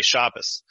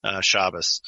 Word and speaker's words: Shabbos: They say Shabbos, which notice it shifts the T sound to an S, and it shifts the syllable emphasis to the first syllable. And it sounds more like German. It sounds more like, you know, the languages that Yiddish was influenced by, Shabbos.